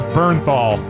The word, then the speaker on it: Bernthal: That means last week on the lists, Bryce put forward John Merenthal for Undoubted, which meant... – I think that's Bernthal. – Whatever. – You're putting too many... – Too many oons? – Too many syllables, man. – Bernthal, thank you.